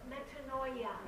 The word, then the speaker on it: metanoia: The Greek is the word metanoia. I think I really butchered that one. But yeah, cool. Say it again. – Metanoia. – Yes, metanoia.